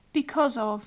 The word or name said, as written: because of